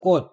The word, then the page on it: 꽃: Korean